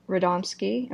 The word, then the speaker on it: Radomski: So this one was directed by Bruce Timm and Eric Radomski. Am I saying that right? – You are saying that correctly, madam.